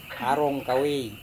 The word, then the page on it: arongkawei: Serewen